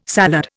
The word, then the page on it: salad: Indian